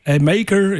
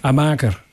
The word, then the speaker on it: Amaker: Amaker, ja, hoe spreek je dat uit? Amaker, zeggen we in Nederlands. Amaker. – Amaker, ja, yeah, I would say so.